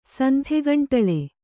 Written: ಸಂಥೆಗಂಟಳೆ

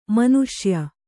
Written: ಮನುಷ್ಯ

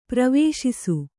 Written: ಪ್ರವೇಶಿಸು

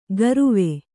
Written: ಕಾರುವೆ

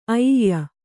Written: ಐಯ

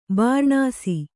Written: ಬೂರ್ನೀಸು

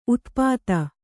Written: ಉತುಪಾತ

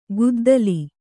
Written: ಕುದ್ದಲ

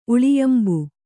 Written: ಉಳಿಯಂಬು

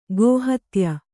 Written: ಗೋಹತ್ಯ

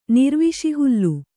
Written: ನಿರ್ವಿಷಿಹುಲ್ಲು

